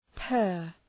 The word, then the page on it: per: pɜ:r